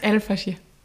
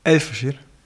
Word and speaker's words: Al-Fashir: Es heißt Al-Fashir. – Al-Fashir.